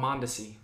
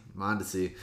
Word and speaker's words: Mondesi: Oh, Mondesi. – Mondesi.